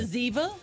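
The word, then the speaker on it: zyzzyva: Oh, it's Z-Y-Z-Z-Y-V-A. – Yeah. Zaziva? – That's exactly how you say it.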